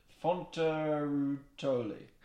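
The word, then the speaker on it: Fonterutoli: F-O-N-T. Yeah, so you pronounced it wrong. Should we pronounce it right before you go ahead? – Yeah, let's do it. Let's do that. – So it's Fontarutoli.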